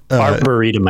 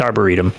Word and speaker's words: arboretum: It's a strange word, but I think it's Arboretum. – Yeah, almost like a burrito. So Arboretum.